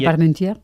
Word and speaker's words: Parmentier: La Parmentier. – Eso es. – Bien, lo dices así y mira. – Bien pronunciado.